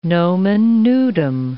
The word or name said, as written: nomen nudum